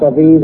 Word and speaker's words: Aviv: Before the Babylonian captivity, it was called month of green ears, or Aviv. A-B-I-B, which was meant to be pronounced Aviv as in Tel Aviv.